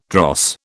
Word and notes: dross: British English

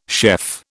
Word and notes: chef: British English

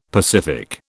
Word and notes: pacific: British English

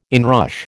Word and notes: inrush: American English

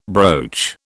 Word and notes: broach: British English

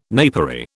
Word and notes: napery: British English

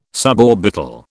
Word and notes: suborbital: British English

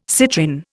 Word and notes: citrin: Indian

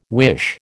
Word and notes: wish: American English

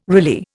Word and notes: Riley: Indian

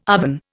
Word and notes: oven: Indian

